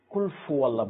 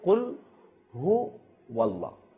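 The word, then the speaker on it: কুল হু ওয়াল্লাহ (قل هو الله): তো এটার ক্ষেত্রে আমরা অনেক কিছু ভুল করে থাকি, সেটা হচ্ছে কুল ফু আল্লাহ বলে থাকি, অর্থাৎ উচ্চারণ হচ্ছে কুল হু ওয়াল্লাহ, কিন্তু আমরা বলে থাকি যেটা না জানার কারণে কুল ফু আল্লাহ, অর্থাৎ হার স্থানে আমরা ফার উচ্চারণ করি।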